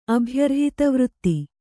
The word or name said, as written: ಅಭ್ಯರ್ಹಿತವೃತ್ತಿ